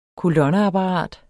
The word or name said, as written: kolonneapparat